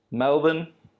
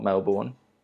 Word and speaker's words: Melbourne: So your options are Melbourne, pronounced correctly, not Melbourne, Auckland, Sydney, or Perth. Apparently it's okay, a thing in Melbourne that you have to say Melbourne. You can't say Melbourne.